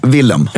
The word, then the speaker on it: Willem: Man säger alltid fel. Willem Dafoe. Willem. – Ja. – Mm. Alltså uttalat på svenska i stort sett.